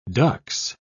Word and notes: dux: British English

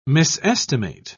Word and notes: misestimate: British English

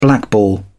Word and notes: blackball: British English

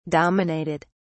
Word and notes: dominated: British English

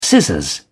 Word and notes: scissors: British English

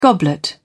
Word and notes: goblet: British English

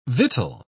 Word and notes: victual: British English

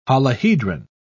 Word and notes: holohedron: British English

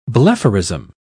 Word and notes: blepharism: British English

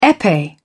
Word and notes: epee: British English

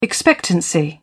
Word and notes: expectancy: British English

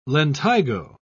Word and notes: lentigo: British English

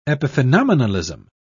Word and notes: epiphenomenalism: British English

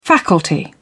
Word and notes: faculty: British English

